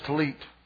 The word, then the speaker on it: tallit: Let me tell you some things about this tallit as it preaches to us this morning. Number one, the tallit, and that's spelled T-A-L-L-I-T. In Pauline, we'd call it a tallit. T-A-L-L-I-T, the tallit. The tallit reveals the Word of God by its fringes.